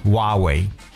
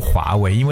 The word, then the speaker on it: 华为: 前段时间呢，我们知道这个对我们中国的这个一个公司华为报道特别多。那说到华为呢，其实很多外国人看到华为的拼写会直直接读成蛙为。很多外国人读华为不读华为，因为它那个 h 按照他们的这种直觉来讲是不发音的。